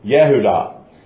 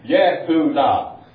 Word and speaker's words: Yehudah: So Jacob, as we said, is going to have a son, and his name is going to be Judah, or Yehudah is how it's pronounced in Hebrew. Yehudah.